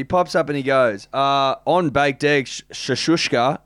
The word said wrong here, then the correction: shakshuka: Shakshuka. – Shashuka. – Shakshuka.